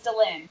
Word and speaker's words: Delenn: Her name, I totally mispronounced her name earlier. I'm sorry, guys. I had it's Delenn.